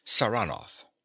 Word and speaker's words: Saranoff: He pronounces the name with a stress on the second syllable, Saranov.